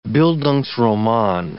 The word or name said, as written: bildungsroman